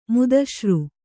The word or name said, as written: ಮುದಶ್ರು